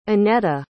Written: anetta

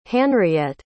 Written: hanriette

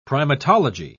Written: primatology

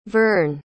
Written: vern

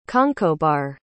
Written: conchobar

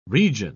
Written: region

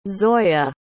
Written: zoyah

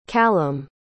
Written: callum

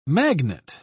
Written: magnet

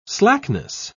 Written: slackness

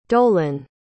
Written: dolan

